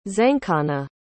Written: zankhana

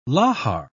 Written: lahar

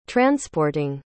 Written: transporting

